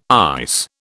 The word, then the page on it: ICE: British English